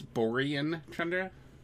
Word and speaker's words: Borean Tundra: Or was it Boring Tundra? Might have been Boring Tundra. – I think you're mispronouncing that. It's Borean Tundra.